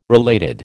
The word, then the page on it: related: American English